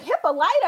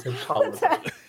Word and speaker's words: Hippolyta: And okay, so wait, and another thing that got me is the pronunciation of Hippolyta's name. I was like, Hippolyta? I was like, I'm pretty sure it's Hippolyta.